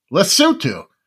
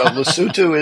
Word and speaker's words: Lesotho: Lesotho. – Very good. So Lesotho is a country surrounded by South Africa.